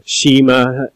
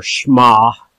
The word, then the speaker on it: Shema: And we introduced last week what's called, us English-speaking people say Shema, but in Hebrew it's pronounced more Shema.